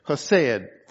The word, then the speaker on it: chesed: The word kindness there is the Hebrew word H-E-S-E-D, and I can't say it properly because it sounds like a furball when you say the K-H together in a cat, but chesed, for lack of something better, okay?